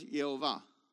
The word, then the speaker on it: Yehovah: So I did an experiment. I typed Yudhei Vavhei in the Google app for Hebrew and hit the say it. And what came out was Yehovah.